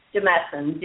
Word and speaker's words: Demasson: Demasson, DEMASSON.